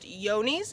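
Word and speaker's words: Johne's: How it seems to be spelt, but it's actually pronounced yonis.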